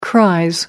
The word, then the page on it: cries: British English